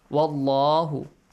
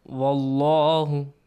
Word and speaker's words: والله: والله the word the letter lam and the word Allah must be pronounced with a heavy sound. Am I right? – Yes. – So, pronounce it. Wallahu. – Wallahu.